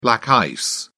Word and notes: black ice: British English

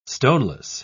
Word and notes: stoneless: British English

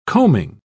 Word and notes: combing: British English

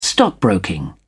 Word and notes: stockbroking: British English

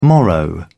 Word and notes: morrow: British English

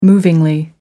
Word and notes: movingly: American English

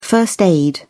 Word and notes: first aid: British English

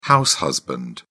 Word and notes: house husband: British English

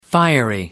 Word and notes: fiery: American English

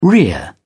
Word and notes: rear: British English

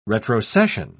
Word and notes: retrocession: British English

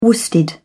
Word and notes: worsted: British English

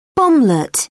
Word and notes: bomblet: British English

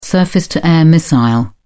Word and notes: surface-to-air missile: British English

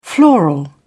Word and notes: floral: British English

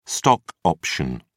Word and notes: stock option: British English

